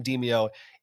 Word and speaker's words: Demeo: I believe that's how you pronounce it. It's Demeo. I think it's kind of a bad title because I kept thinking it looks like the word demo.